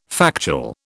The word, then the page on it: factual: British English